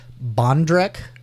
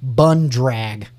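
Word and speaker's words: bandrek: This one, I would pronounce this Bondrek, but it's actually pronounced Bundrag.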